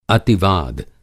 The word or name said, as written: अतिवाद